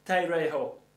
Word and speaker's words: tereo: If you look at the word keep there, the word keep actually is a Greek word that is said like this, tereo, tereo. It's spelled T-E-R-E-O, tereo.